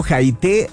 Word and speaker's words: Jaité: Y búscalos en redes sociales, también no se te olvide, están como Jaité Restaurante, así, con J, Jaité, con acento en la E, Restaurante.